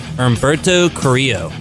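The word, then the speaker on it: Humberto Carrillo: You don't pronounce the L's in this name. Umberto Carrillo.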